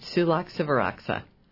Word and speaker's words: Sulak Sivaraksa: It's Sulak Sivaraksa.